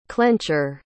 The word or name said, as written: clencher